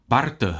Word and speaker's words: Barth: Barth, nepleteme si ho s Rolandem, Barth, SM, jak se někdy v té výslovnosti se to potom plete.